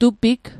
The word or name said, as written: tupig